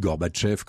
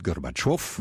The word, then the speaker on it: Gorbatchev: Oh, si vous le dites à l'occidental, vous dites Gorbatchev comme on disait Khrouchtchev.